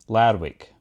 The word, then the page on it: Lerwick: Shaetlan